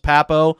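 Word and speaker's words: Pappoe: If you're talking about a guy who can really, really get after from an athleticism standpoint, Owen Papo, who's it's P-A-P-P-O-E is how you spell his last name out of Auburn. It's pronounced Papo.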